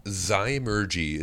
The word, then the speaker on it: Zymurgy: because whether it's Zymurgy or Zymurgy or any of a million other things, what is the correct pronunciation? Where did that name come from? How'd you name your brewery? – Zymurgy is how you would pronounce it.